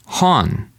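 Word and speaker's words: Hon (本): Hon.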